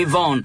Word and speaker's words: Vaughan: Von. – Bravissimo. – Ho detto bene, eh? – Ha detto perfettamente, perché noi italiani scivoliamo sul Vaughan. – Sì. – In realtà la pronuncia esatta è Jimmy Von, che nacque nel 1951, chitarrista e compositore americano statunitense, fratello del più celebre, del più famoso Steve Ray Vaughan.